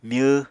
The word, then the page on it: myw: Apatani